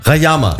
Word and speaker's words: Rayama: Wie habe ich den richtig ausgesprochen? – Rayama, genau. – Rayama, ne? So rum. Also da haben wir das.